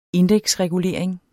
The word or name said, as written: indeksregulering